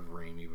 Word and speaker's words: Ramey: R-A-M-E-Y. – Either could be right, but I've heard of Ramey before, so, but anyway. – Okay, I'll say Ramey. – Okay. – Mary was raised by her mother, Rebecca Ramey, and grew up in a family which included her older brother, sister, grandmother Harriet Carrington, and her uncle Edward H.